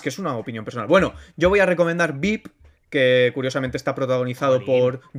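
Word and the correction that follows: Veep: Vip, lo he dicho mal. Eh, Mar, corrígeme. – es que tienes que alargar un poco más la I porque si no es VIP como VIPs el ah, restaurante.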